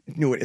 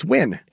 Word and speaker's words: Nguyen: It's like, no, it's not Nguyen. It's Win.